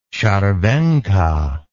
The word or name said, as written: scharwenka